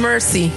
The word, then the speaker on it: Mercy: Hi, for those of you that don't know me, my name is Mercy, just like it's spelled in the Bible.